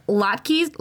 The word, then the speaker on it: latkes: Potato pancakes, known as latkes.